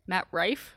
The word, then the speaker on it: Matt Rife: Matt Rife. – Mm-hmm. – Is that how you say his name? – Yeah.